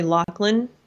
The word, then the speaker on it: Loughlin: I always have said her name wrong. I guess it's Lori Loughlin, clearly an Irish name.